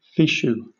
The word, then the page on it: fichu: British English